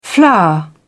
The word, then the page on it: flour: British English